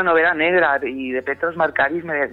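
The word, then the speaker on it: Markaris: Marcaris.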